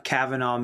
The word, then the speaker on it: Kavanah: And then, they can go to my website, Kavanaugh Media. So it's spelled K-A-V-A-N-A-H Media. It's actually a Hebrew word.